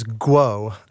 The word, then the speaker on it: Guo: Her last name is Guo.